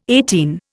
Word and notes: eighteen: Indian

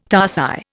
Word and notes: tarsi: Indian

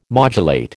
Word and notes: modulate: American English

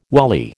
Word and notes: wally: American English

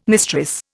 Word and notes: mistress: Indian

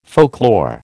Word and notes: folklore: American English

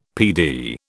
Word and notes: PD: British English

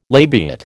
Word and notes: labiate: American English

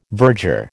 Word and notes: verger: American English

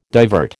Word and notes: divert: American English

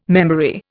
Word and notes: memory: Indian

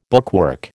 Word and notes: bookwork: American English

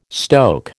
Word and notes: stoke: American English

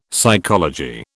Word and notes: psychology: British English

